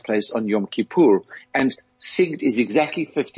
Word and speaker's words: Sigd: How do you pronounce it? Sigd. – Sigd. Sigd. Right.